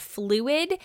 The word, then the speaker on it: Flewd: called Fluid, and it's spelled F-L-E-W-D.